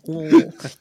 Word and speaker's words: Louisville: It's fun to say Louisville. You just kind of swallow your syllables.